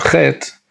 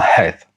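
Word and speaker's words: Het (חית): The next letter, which I as a child was taught, is identical to another letter in Hebrew, namely the Chaf, and therefore I was taught to pronounce it as a Chet, is not a Chet, but rather it is a Het.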